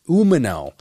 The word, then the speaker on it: Umino: Umino. I'm saying that wrong. It's Umino.